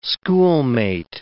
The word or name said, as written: schoolmate